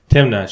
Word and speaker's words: Timna: Timna? Is that how you pronounce it? – Timna.